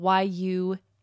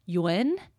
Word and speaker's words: yuan: It's spelled Yuan. And turns out that it's actually pronounced in Mandarin something closer to Yuan, or like the letter U-N, Yuan.